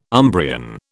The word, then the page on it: Umbrian: British English